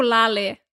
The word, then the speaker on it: Laleh: Yo escucho un grupo que se llama Lale. Lale, se escribe L-A-L-E-H. Lale.